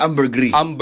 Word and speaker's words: ambergris: Haka na siyang ambergris. Mone sila ambergris. – Ambergris. Mura siyang debris. Dire siya ambergris.